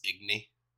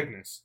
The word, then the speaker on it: Ignis: For the grammar not saying, it's Igni. The S is silent. – No, it isn't. – Yes, I'm pretty sure. – It's Ignis. – It's Ignis. I just played it.